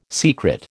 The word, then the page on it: secret: American English